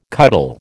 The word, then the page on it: cuddle: American English